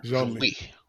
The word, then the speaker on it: Genlis: Is it Genlis? – Genlis? Okay. – Yeah. – Genlis.